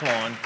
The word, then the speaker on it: Lawn: My name is Mike Lawn, and yes, it's spelled just like the grass.